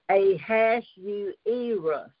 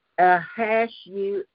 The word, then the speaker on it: Ahasuerus: Here it is, Ahasuerus. It's a five-syllable name, Ahasuerus, five syllables.